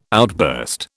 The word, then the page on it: outburst: British English